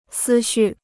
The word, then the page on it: 思绪: sī xù